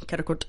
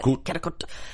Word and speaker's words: Karakurt: Karakurt. I have to say it like that. – Karakurt.